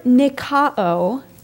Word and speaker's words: Nikao: And then there's the word Nikao, N-I-K-A-O, Nikao, which means to subdue, to prevail, to win a victory, to conquer. This word Nikao, to conquer, to overcome, is used 15 times in the book of Revelation.